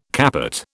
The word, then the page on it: capot: British English